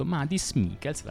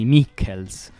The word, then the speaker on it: Mihkels: Gli ho chiesto di pronunciare esattamente il suo nome e lui ha detto Madis Mikkels, l'avete sentito? È piuttosto semplice, c'è soltanto quell'H prima della K nel cognome, quindi si scrive M-I-H-K, quindi Mikkels.